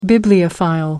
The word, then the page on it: bibliophile: British English